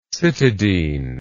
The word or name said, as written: cytidylic